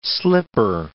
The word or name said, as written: slipper